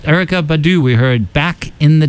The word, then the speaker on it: Erykah Badu: Erykah Badu. – Erykah Badu.